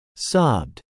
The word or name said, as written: sobbed